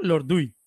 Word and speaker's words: Lorduy: Está hablando Lordui, si lo pronuncia bien, ¿no? Lordui. Lord perfecto. Oh my God, por fin.